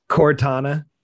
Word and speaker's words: Cortana: Cortana.